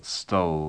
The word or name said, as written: stole